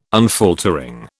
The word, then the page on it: unfaltering: British English